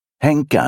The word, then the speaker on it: Henka: Henka är en snuttifierande förkortning av Henrietta och uttalas med ett ängljud lika ödmjukt som hennes själ.